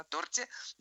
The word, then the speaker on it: торт: И я в качестве запоминания рекомендую именно это выражение, да, потому что вроде как бы здесь устоялось на торте, но это не только в этом выражении такое ударение, оно и везде такое ударение. Вот, поэтому торт, торта, торту.